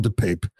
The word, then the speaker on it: DePape: I've seen his name pronounced five different ways over and over again. I'm going to call him DePape.